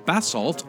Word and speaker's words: Basalt: the Arts Campus at Willits in Basalt, although I've also heard it's pronounced Basalt on April 21st, and the Lone Tree Art Center in Lone Tree on April 22nd.